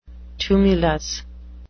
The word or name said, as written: tumulus